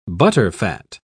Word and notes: butterfat: British English

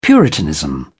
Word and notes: puritanism: British English

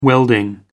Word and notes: welding: American English

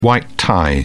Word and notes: white-tie: British English

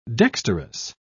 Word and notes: dexterous: British English

